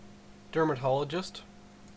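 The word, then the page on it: dermatologist: Canadian English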